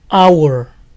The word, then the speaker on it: hour: La H inicial en la mayoría de palabras en inglés suena a j, pero estas dos palabras son la excepción, se pronuncian con H muda. Hora, hour, hour. Honor, honor, honor. Es como si empezaran con sonido de vocal.